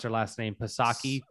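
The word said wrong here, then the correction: Psaki: Saki. – Is yeah. it is it Saki?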